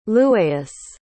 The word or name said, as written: lueius